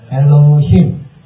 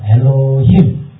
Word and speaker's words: Elohim: Elohim，这有英文发音啊，所以你可以跟着念，叫 Elohim。这是在跟我一起念 Elohim。啊，这个很容易记嘛， Elohim。那英文就是 God，独一的神，啊，独一的神。好，那我们在这边看的时候啊， Elohim 我们又叫做是神，也可以称作是上帝。